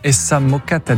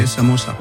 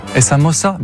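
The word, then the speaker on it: Essa Moça: Ah non, Essa Mocata Differente. – Non, c'est Essa Mossa. – Essa Mossa. Ben vous voyez, je l'avais dit que vous le disiez mieux. – Essa Mossa.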